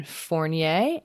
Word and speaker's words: Fournier: And then Fournier, F-O-U-R-N-I-E-R. – Yeah, that was great. – Perfect. Fournier